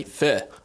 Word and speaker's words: pho: That's how you say it correctly. Pho. – Oh, really? – Not pho.